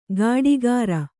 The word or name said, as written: ಕಾಡೀಖಾರ